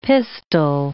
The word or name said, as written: pistil